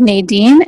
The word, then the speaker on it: Nadine: It's my name is spelled Nadine, N-A-D-I-N-E, Haruni, H-A-R-U-N-I.